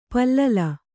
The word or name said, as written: ಪಲ್ಲಿಲಿ